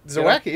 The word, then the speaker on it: Zawacki: Zawacki, yes.